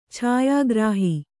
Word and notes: ಛಾಯಾಗೃಹ: chāyā gřha

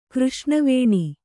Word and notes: ಕೃಷ್ಣವೇಣಿ: křṣṇa vēṇi

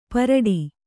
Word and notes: ಪಾರಟ: pāraṭa

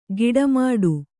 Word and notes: ಗಿಡಮಾಡು: giḍa māḍu; Kannada

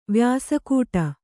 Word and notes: ವ್ಯಾಸಕೂಟ: vyāsa kūṭa; Kannada

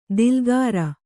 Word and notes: ದಿಲ್ಗಾರ: dilgāra

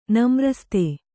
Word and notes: ನಮ್ರಸ್ತೆ: kannada

